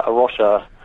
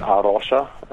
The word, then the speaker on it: A Rocha: So Aroche is a Portuguese word. So might be worth giving a bit of a history of Aroche. So Aroche. So that's how you pronounce it, Aroche.